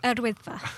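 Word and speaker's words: Yr Wyddfa: Recent hot weather means there's been a bumper summer crowds at Yawadifa. What's that how you pronounce it? – Erwidfa. – Thank you.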